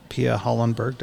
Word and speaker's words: Pia Hallenberg: And today we have Pia Hallenberg. Did I say that right? – Yeah, you said that. – Okay, right. – Pia Hallenberg.